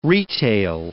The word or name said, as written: retail